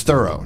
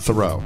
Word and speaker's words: Thoreau: It's Thorough, not Thoreau. – It's actually Thorough. – Thorough. Oh, damn it. – Oh, wow. – I always get it wrong. It's Thorough. – Thorough. – It's exactly. If you go there, they'll tell you you're saying it wrong.